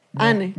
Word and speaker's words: Anne: Eh, Anne, ¿cómo se dice Anne? ¿Tú sabes más francés que yo? Ane.